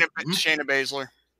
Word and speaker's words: Baszler: Shayna Baszler.